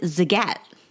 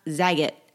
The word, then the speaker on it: Zagat: You pronounce it Zagat. – I know. – It's not Zagat.